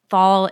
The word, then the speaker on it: Thal: Thal is spelled T-H-A-L.